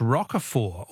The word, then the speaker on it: Roquefort: The French Roquefort, also with a silent T, is very smelly.